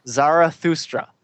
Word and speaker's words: Zarathustra: Thus. Repeat after me. Thus. – Thus. – Sprach. – Sprach. – Zarathustra. – Zarathustra. – There you go.